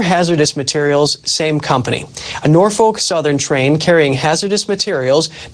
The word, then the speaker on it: Norfolk: For people out there who want to know, it's pronounced Norfolk.